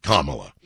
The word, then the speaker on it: Kamala: That's pronounced Kamala.